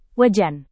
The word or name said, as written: wajan